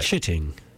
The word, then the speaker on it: chitting: You wouldn't ordinarily cut the potatoes into little pieces and then, what do you call it, kitting? How do you pronounce that? – Chitting.